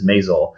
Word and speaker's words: Maisel: The Marvelous Mrs. Maisel.